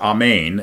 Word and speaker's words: Amen: The word Amen, or Amen, is the correct pronunciation of it, is actually a Hebrew word.